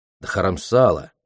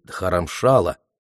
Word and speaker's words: Дхарамшала: Дхарамсала или Дхарамшала, как это название произносится на хинди, означает «духовная обитель».